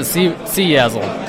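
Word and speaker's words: CyaSSL: We're at CYASL, is that right? – We pronounce it CYASL.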